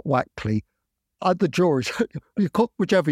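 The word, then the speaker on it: Wakley: pointed out to me that they pronounce him Wackley rather than Wakely, because I had pronounced him Wakely up until then.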